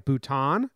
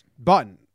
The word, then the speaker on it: Button: I was going to pronounce it like Bhutan. But it's right. Button.